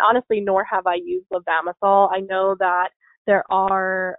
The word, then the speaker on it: Levamisole: Levamisole? – Levamisole. – Levamisole.